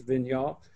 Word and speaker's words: Vigneault: the coach, Alain Vigneault, I never know how to say his last name real well, but it's Vigneault.